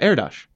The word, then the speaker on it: Erdős: Yeah, Erdős.